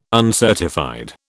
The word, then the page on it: uncertified: British English